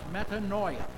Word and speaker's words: metanoia: The Greek word used in the New Testament for repentance is pronounced metanoia.